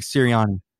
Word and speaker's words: Sirianni: Nick Sirianni.